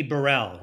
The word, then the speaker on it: Burrell: And I, I, I like some of the same music, but I'll have to check in on this uh, Kenny Burrell, right? That's how you say it, Burrell.